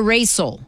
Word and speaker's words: Raycel: Raycel. – Spell that for me. – R-A-Y-C-E-L. – Am I pronouncing, is it Raycel or Raycel? – Raycel.